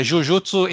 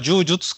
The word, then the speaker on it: Jujutsu: Aqui eu, eu, eu vou usar pronúncia japonesa porque fala Jiu-Jitsu, é, Jujutsu, enfim, é, em termos de Japão, é, tem um significado completamente diferente. Então, só para deixar bem claro do que, estou falando, vou falar Jujutsu, que é pronúncia japonesa mesmo, espero que não tenha problema.